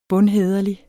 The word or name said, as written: bundhæderlig